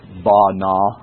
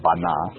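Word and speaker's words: bana (בנה): The word bana, I love the way that Strong's other Hebrew dictionaries make you do it. It has a bana, bana.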